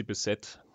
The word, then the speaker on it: Bisset: Interessant, ich habe nämlich diese Frau immer französisch ausgesprochen. Ah, jetzt gerade. – Ja, ich glaube, man spricht sie Bissett. – Ja wir sind in Amerika.